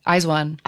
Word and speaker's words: IZ*ONE: IZ*ONE.